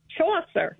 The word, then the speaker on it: Chaucer: How do you pronounce it? Chaucer? – Chaucer.